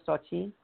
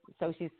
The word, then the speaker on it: Xochitl: And so I've just, over the years, Xochitl's fine. So she went to the store. But if you pronounce it correctly, it's Xochitl or Xochitl.